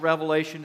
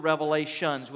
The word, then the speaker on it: Revelation: I'm going to share with you one of my picky things about the book of Revelation to start off, okay? If you really want to grate on my nerves, pronounce it the book of Revelations with an S on the end of it, and that would just drive me nuts, okay?